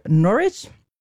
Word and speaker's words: Norwich: Het speelt zich af in 1901 in het Engelse stadje Norwich.